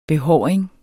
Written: behåring

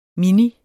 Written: mini

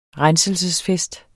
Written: RENSELSESFEST

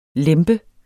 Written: lempe

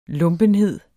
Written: lumpenhed

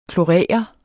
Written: klorere

